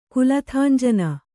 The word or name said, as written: ಕುಲಥಾಂಜನ